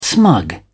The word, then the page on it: smug: British English